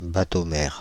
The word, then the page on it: bateaux-mères: France (Île-de-France); /ba.to.mɛʁ/